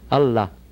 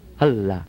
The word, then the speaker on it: Allah: Aleph is ah. Make the sound ah. Okay, two L's, lala, and ha. Allah, Allah.